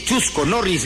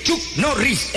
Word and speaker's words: Chuck Norris: No se dice Chusco Norris, se dice Chuck Norris.